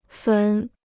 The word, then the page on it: 坟: fén